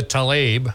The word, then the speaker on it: Tlaib: But Rashida Tlaib, I'm probably mispronouncing her name.